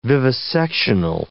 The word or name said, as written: vivisectional